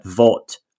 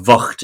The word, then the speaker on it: Vught: One of the HQ locations for the division is actually at the old concentration camp at Vought, or Vught, as it's pronounced, I'm led to believe.